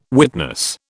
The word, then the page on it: witness: British English